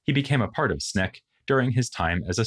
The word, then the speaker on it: SNCC: He was at this time a member of the SNCC, Student Nonviolent Coordinating Committee, or SNCC, as it was often pronounced.